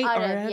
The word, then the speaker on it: Arev: Am I saying that right? – Arev, yes. – Arev, okay, yes.